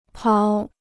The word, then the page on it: 跑: páo